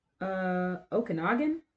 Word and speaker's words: Okanagan: But yeah, this is in the, I believe it's pronounced Okanagan.